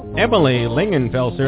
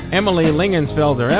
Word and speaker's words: Emily Lingenfelser: Emily Lingenfelser,